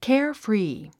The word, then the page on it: carefree: kέərfrìː; ケアフリィ